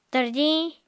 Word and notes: tarjiñ: Apatani

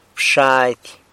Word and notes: bxady: San Lucas Quiaviní

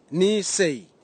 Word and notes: nisei: Nafe; Tanna

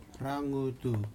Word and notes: ragutu: slow

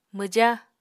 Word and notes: mwja: Apatani

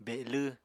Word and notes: belw: Apatani

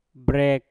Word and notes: brek: Mpur

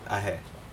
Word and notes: ahe: Aneityum

